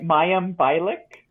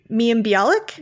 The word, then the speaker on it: Mayim Bialik: Mayim Bialik. – Mayim Bialik? – Yes, that one.